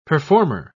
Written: performer

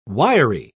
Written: wiry

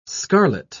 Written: scarlet

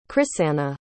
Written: crisanna

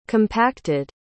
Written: compacted

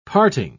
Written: parting

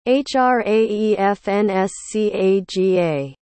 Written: hraefnscaga

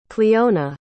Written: cliona

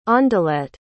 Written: ondelette

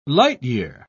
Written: light-year

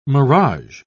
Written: mirage

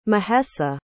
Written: mahesa

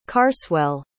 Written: carswell

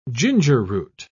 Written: gingerroot

